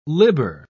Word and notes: libber: British English

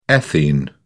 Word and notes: ethene: British English

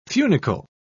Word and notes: funicle: British English

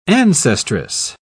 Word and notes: ancestress: British English

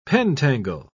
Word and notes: pentangle: British English

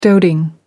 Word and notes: doting: American English

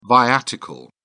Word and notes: viatical: British English

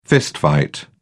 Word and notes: fistfight: British English

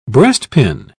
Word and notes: breastpin: British English

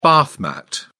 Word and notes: bath mat: British English

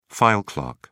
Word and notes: file clerk: British English

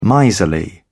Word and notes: miserly: British English

